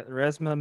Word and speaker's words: Resmaa: Yeah, Resma Menachem.